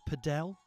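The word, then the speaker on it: padel: It's pronounced paddle. We've been putting the wrong emphasis on the wrong syllable all the time.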